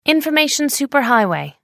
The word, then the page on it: information superhighway: American English